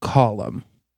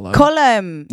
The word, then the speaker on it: Colm: XOXO column." I believe I'm pronouncing that right. They literally wrote the name C-O-L-M and then put in parentheses how to pronounce it. I believe it's an Irish name. Colum. Yeah.